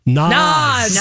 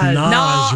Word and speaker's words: Nas: Naz. – Nas. It's Nas, Ruby says. – Nas. Long A Nas. Like the rapper Nas.